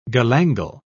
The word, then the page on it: galangal: British English